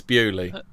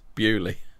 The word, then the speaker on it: Beaulieu: It's Beaulieu. – But it's spelled Beaulieu. – But it's spelled, but it's pronounced Beaulieu.